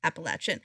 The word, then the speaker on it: Appalachian: It's actually pronounced Appalachian.